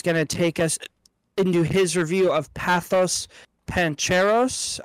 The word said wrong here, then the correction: Pather Panchali: I believe I'm saying that right. – I think it's Pather Panchali.